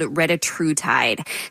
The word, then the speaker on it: retatrutide: The new drug is called retatrutide.